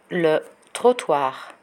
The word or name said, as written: le trottoir